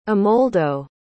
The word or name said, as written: amoldo